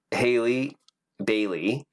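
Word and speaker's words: Halle Bailey: And in that fucking moment, Haley Bailey.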